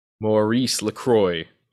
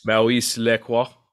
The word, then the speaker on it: Maurice Lacroix: All right, up next, my next choice is, I'll give you the American pronunciation first, which would be Maurice LaCroix. But if you want to get all fancy schmancy, it'd be, I guess, Maurice LaCroix. It's the Icon. I guess that's how you say yes. – It. – A-I-K-O-N.